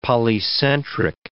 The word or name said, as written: polycentric